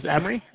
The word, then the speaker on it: Emre: My name is Emery. It's spelled E-M-R-E.